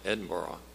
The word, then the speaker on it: Edinburgh: I want to commend you for pronouncing it right, Edinburgh.